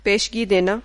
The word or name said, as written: पेशगी